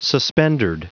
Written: suspendered

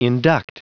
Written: induct

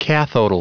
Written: cathodal

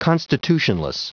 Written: constitutionless

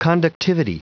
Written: conductivity

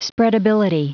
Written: spreadability

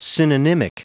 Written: synonymic

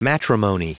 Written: matrimony

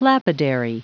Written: lapidary

